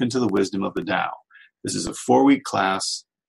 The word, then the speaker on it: Tao: And it's spelled T-A-O, but pronounced like a D, Tao.